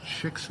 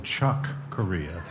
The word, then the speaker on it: Chick: And Mel Torme announced, you could actually look at the footage, he announced a chick's name wrong. He called him Chuck Corea. And Ella Fitzgerald said, no, Mel, his name is Chick.